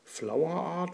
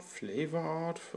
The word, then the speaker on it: Flavourart: Tja, wie spricht man es jetzt aus? Ich habe es wieder gefunden. Flower Art, Flavor Art, Liquids. Ich buchstabiere mal FLAVOURART.